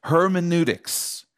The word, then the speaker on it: hermeneutics: The word is pronounced hermeneutics,